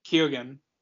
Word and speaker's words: Keoghan: And Barry Koenig. Is that his name? – Yeah. – Did I say it right? – No, I was laughing at the Sarah Hall thing. – Barry Cohen. Koenig? – Keoghan.